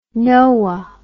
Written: noaa

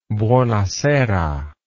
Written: buonasera

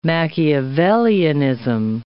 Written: Machiavellianism

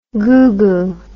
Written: gugu

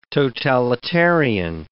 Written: totalitarian

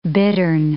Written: bittern

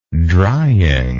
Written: drying

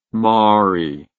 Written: mari

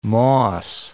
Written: moss

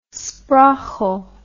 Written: sprauchle

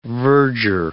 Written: verger

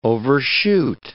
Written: overshoot

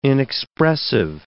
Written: inexpressive

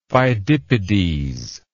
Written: Phidippides

